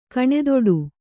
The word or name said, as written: ಕಣ್ತಡೆ